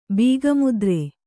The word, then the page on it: ಬೀಗಮುದ್ರೆ: Kannada; bīga mudre